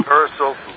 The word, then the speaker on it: Paracelsus: The elemental creatures that are part of the alchemy and the Paracelsus. Am I pronouncing his name right, Paul? – Good enough. We get it. – Yeah. – Yeah, Paracelsus.